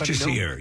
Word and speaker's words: Jacir: I think it's Jassir. Yeah. – Okay. – Yeah. – J-A-C-I-R.